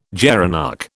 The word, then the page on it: gerenuk: British English